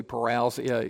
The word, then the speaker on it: parousia: When Paul spoke of Christ coming in 1 Thessalonians, he used the word parousia. I've heard some people say parousia. It can be pronounced either way.